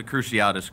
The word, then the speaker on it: Cruciatus: The Cruciatus curse. – The Cruciatus curse, which hits very close to home with him.